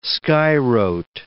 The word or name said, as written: skywrote